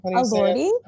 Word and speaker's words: Elordi: Eldwey? – Elordi? – Yes.